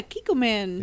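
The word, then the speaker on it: Kikkoman: Shout out to Kiko Man. That's some good shit. – Hell yeah. – Is that how you say it? – Yeah, Kiko Man.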